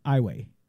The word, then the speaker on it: Iowa: Yeah, it's spelled Iowa. Zero. – It is pronounced Iway.